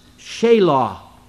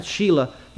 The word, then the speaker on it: Shelah: She had three sons, Onan, and I believe it's pronounced Shelah. Not Sheila. You know, I don't think I'd have any respect for a boy named Sheila. But his name was Shelah, so we could pronounce it that way.